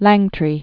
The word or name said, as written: Langtry